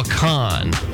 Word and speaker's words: Kahan: Oh, Noah Kahan. – Yeah. – Like con artist. – Yeah, that's it.